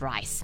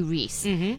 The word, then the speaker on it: Rhys: It was the 78th Primetime Emmy Awards, and Matthew Rhys, I think is how you say his name, not Rice, Matthew Rhys, made history as the first actor to win two lead Emmy Awards in a single night.